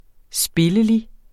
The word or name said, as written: spillelig